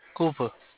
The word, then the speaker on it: Cooper: Cooper. – Could you spell it Cooper, is it? – Yeah, C-O-O-P-E-R, Cooper.